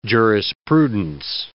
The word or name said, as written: jurisprudence